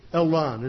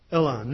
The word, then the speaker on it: Elyon: El Elan. It's the last one is spelled E-L-Y-O-N. Elan.